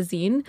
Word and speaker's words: zine: When we were in person and I went to the podcasting fair, a lot of podcasts also had a zine. So I have it on good authority from a lot of hipster podcasters. That is how it is pronounced.